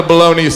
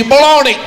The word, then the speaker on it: bologna: Bologna! I don't know if you saw, there's a G in the word. I don't see no G, baloney.